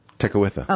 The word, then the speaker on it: Tekakwitha: How do you say her last name? – Tekakwitha.